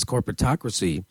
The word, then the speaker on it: corporatocracy: I feel like we need to set the table more about this corporatocracy. You know, you talk about how... – Was I saying it right in the intro? – No, you're saying it right. You're saying it correct.